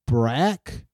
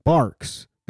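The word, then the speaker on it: Barq's: Root beer, B-A-R-Q. It's Breaks? How do you pronounce this? Leave me alone, Google. Barks. It's called Barks? Well, that makes sense. I'm just retarded and thought you had to pronounce it with a Q.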